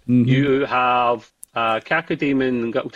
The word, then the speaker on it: Cacodemon: It's got a floating, and I never know how to pronounce this, one of the demons. Cacodemon. Cacodemon. Thank you.